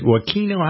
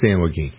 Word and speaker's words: San Joaquin: The story of Coco, a pit bull, went missing from a home in San, is it Joaquino? How do you pronounce that? – San Joaquin? – Joaquin. No, it's San Joaquin.